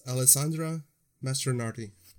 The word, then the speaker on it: Alessandra Mastronardi: The name is Alessandra Mastronardi. And if it sounds weird, it's because it's Italian. And I'm not mispronouncing it. That's really how it's spelled out and how she pronounces it.